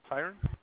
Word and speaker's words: Tyren: My name is Tyron. – Could you please spell that for me? – T-Y-R-E-N.